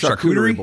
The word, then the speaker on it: charcuterie: Charcuterie board. – Yeah. – Implements. – In the ad, it actually has a charcuterie board in there. – That is hilarious. I love me a charcuterie board, dude.